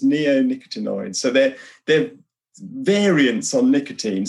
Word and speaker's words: neonicotinoids: And the very first thing I asked you off camera was how to say this word, neonicotinoids.